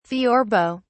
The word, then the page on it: theorbo: British English